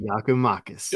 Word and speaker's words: Giakoumakis: Giakoumakis. I said it right, right? – Giakoumakis.